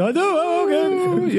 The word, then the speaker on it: Hadouken: That really long O that the Ryu adds. – It's spelled like O-U-O-U-O-U, Hadouken.